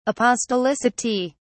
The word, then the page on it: apostolicity: British English